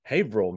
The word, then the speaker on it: Haverhill: Well, and um, Faig reported back that we mispronounced the name of the town. It's Haverhill, Massachusetts.